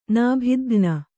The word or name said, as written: ನೌಬತುಖಾನೆ